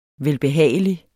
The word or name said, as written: velbehagelig